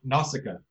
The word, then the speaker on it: Nausicaa: We're going to be watching Nausicaa of the Valley of the Wind. Nausicaa. – Mm-hmm. Yeah. – That second one was the correct pronunciation.